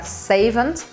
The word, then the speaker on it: savant: Un faux pas a palavra savant, que em inglês eles pronunciam o T e fica savant.